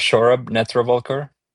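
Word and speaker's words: Saurabh Netravalkar: I will not blame you guys if you don't know how to pronounce his name. He's an Indian guy. His name is Shorab Netravalkar.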